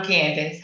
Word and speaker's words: Candyce: I'm Candyce.